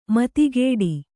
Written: ಮತಿಗಿಡು

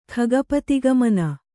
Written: ಖಗಪತಿಗಮನ

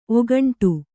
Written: ಒಗಂಟು

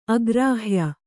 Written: ಅಗ್ರಾಹ್ಯ

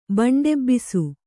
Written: ಬಂಡೆಬ್ಬಿಸು

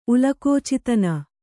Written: ಉಲಕೋಚಿತನ

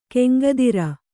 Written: ಗಂಗೋತ್ರಿ

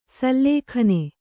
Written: ಸುಲಗ್ನ

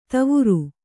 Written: ದೀವರು

